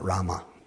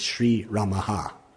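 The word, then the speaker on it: Sri Ramah: it's not Rama. It's Sri Ramaha. So even you're saying it wrong.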